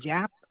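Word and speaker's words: Giap: And then the last name is Jap, G like in George, I, A as in Apple, P as in Peter.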